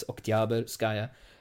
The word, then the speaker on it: Oktyabrskaya: I know that in Russian it's supposed to be emphasis on the middle syllable, so it's Oktyabrskaya.